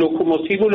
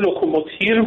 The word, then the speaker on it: لوکوموتیو: حالا آقای رئیسی که خب پروپاگاندا رو پروپاگانی قبلا گفته بود، لوکوموتیو لوکوموتیر گفته بود که مورد تمسخر واقع شد که ایشون اونقدر با تیر تفنگ سر و کار داره.